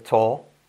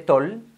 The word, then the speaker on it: tall: Y no se dice tall, sino tall. Manuel, Miguel, Raquel, tall.